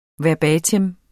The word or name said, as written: verbatim